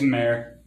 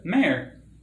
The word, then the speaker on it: Mar: If you want to watch discount Castlevania, there's always Mare. – Mare? – Mare. – What's that? – M-A-R. – M-A-R? Isn't that Mar? – It's Mare. That's how they pronounce it.